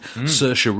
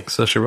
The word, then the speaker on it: Saoirse: But he's surrounded by a great cast, which includes, for instance, Chris O'Dowd, Saoirse Ronan, Jerome Flynn. – I'm so happy that you pronounced her name correctly. – Saoirse Ronan. I love Saoirse Ronan.